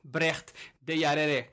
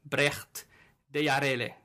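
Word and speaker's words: Brecht Dejaegere: Rouault scored his first goal on a top flight, and then the captain, Brecht Deyarere, if you're wondering how we pronounce it, you can say Brecht, you can read Brecht de Jaeger or something like that on the name. I was watching a video where there was a Toulouse challenge about how to pronounce his name, and there was another Belgium player who said that his name was Brecht Deyarere.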